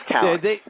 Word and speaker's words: Calyx: about the time you – Calyx – is it Calyx? Calyx? Whatever it is. – I believe it's Calyx.